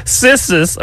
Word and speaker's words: Cissus: Cissus, okay, it's spelled C-I-S-S-U-S. Okay, Cissus.